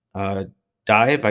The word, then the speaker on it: Dive: And then Mega Man is apparently returning, but it's only for Steam, iOS, and Android with Mega Man X Dive, I guess, D-I-V-E. I don't know if it's separated. It's supposed to be separate or just pronounced no, dive. It's dive.